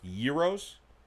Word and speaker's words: gyros: or perhaps it's pronounced gyros. – Gyros, yes.